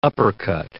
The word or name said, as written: uppercut